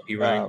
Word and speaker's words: Perine: They're going to use uh, uh, how do you say his name? Perine, Perine. Oh, Perine.